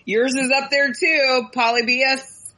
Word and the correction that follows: Polybius: Did I say that wrong? – Yeah. – Polybius.